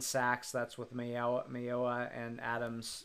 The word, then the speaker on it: Mayowa: Mayowa. – I say Mayowa. I think that's how broadcasters say it most of the time.